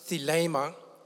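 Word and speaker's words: thelema (θέλημα): If I go to the Greek word for that word, preferred will, it is pronounced thelema.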